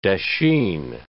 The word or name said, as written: dasheen